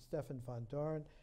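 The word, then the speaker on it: Stefan Vandoren: They took a cue, Gerard de Hooft and Stefan van Doren, the Dutch pronunciation, Stefan van Doren,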